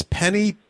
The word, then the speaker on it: Penney: It's Penny, P-E-N-N-E-Y, Peirce, P-E-I-R-C-E. I am pronouncing that right, aren't I, Penny? – Yes, it's just backwards from the way you think it should be spelled.